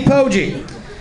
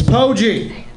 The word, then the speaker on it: Poggi: My name is Steve Poggi. It's hard, kind of hard to pronounce. Typically, when I meet somebody for the first time, I've got to be like, it's Poggi. Like a broke gangster. Poggi.